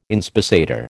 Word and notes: inspissator: American English

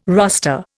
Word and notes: roster: Indian

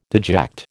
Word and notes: deject: American English

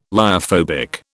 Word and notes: lyophobic: British English

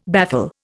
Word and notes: baffle: Indian

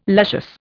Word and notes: luscious: Indian

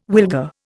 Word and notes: wilga: Indian

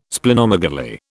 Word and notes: Splenomegaly: British English

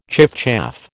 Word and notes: chiffchaff: American English

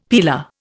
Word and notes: peeler: Indian

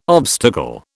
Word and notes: obstacle: British English

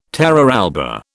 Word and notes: terra alba: British English

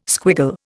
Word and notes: squiggle: Indian

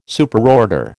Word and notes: Superorder: American English

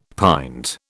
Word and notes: pint: British English

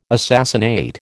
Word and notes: assassinate: American English

